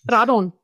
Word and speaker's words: Radon: Die meisten betonen die erste Silbe. Manche sagen auch Radon oder Radon oder so. Also die Mehrzahl der Leute betonen eigentlich die erste Silbe. Aber es hat beides seine Richtigkeit.